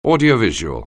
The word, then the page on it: audiovisual: anglais